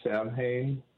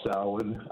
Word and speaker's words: Samhain: You said Samhain? – Yeah, I mispronounced it. – Yeah, it's called Samhain. I'm pagan.